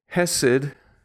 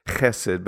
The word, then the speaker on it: Chesed: Hesed. It's properly pronounced Chesed, but I get tired of that, you know, chet. So I just say it the way English people say it.